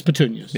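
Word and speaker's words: Puttonyos: Putaños, I believe. – It's petunias. – Yeah. – Yeah, but it's spelled Puttanyos. – Yeah. – Petunias. So it's petunias.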